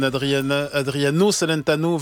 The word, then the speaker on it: Adriano Celentano: Est-ce que je prononce bien Alba Pezzon, Adriano Celentano, 24 Celentano, 24 Milabaci.